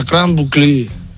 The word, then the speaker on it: Grande Boucle: Yo, ¿sabéis que el Tour de Francia es la Grand Boucle? La Grand Boucle, que se escribe la Grande Boucle. Pues ahora es la Grande Boucle.